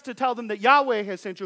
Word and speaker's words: Yahweh: We typically pronounce that name Yahweh.